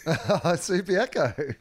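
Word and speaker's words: Subiaco: If rhymes with Booby Echo. – Subi Echo.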